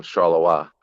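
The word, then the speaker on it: Charleroi: It's C H A R L E R O I. So okay. The O I is the wah part. – Okay. – Charlois.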